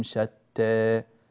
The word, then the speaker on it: شتى: لماذا جئنا بالتقليل ولم نأتي بالفتح؟ لأننا نأتي في مد البدل بأربع حركات، فلذلك جئنا هنا في هذه الكلمة بالتقليل، فتنطق كما بيناها. وقلوبهم شتى شتى بهذه الطريقة بارك الله فيكم وأحسن إليكم.